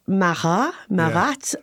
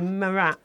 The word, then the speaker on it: Marat: The scientist and political theorist Jean-Paul Marat. I don't know how to say it. – I think you put a T on that, actually. – I think Marat. – You do, don't you? Marat.